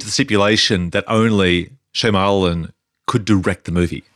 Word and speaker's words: Shyamalan: Yeah, Shyamalan.